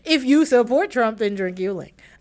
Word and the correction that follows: Yuengling: Um, you mean Yuengling? Yuengling.